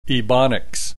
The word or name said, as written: ebonics